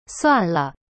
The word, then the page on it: 算了: suan le